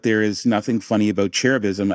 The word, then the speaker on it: cherubism: So our word is cherubism.